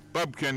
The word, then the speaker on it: Bubka: a pole vaulter by the name of Sergei Bubka. It's pronounced there, Bubka now.